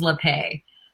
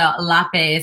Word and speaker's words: Lapaix: So it's Merry Chris, like Merry Christmas, and last name's LePay. But it's French, so LePay is technically the right way, but LePay is fine.